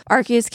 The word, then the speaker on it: Arceus: And I know Arceus came out, and I'm probably pronouncing that wrong again. I always do the hard C. I know it's not a hard C.